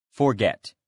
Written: forget

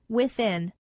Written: within

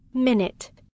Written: minute

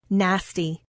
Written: nasty

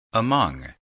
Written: among

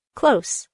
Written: close

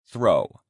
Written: throw

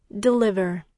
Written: deliver

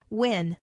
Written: win

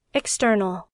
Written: external

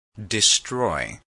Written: destroy

